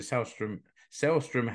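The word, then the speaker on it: Sällström: And I had a lesson in pronunciation during this game, Rich, because I was told that the A with two dots over the top, which you would normally pronounce ah in Finnish, but because Linda Selström has a Swedish name, then that letter is pronounced as an E. So it's Selström.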